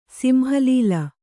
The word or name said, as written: ಸಿಂಹಲೀಲ